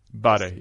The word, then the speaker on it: Bare: And is it called Bare? Like, how do you pronounce the name of this? – Bare, yeah. – Bare.